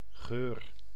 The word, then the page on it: scent: [sent]; /sɛnt/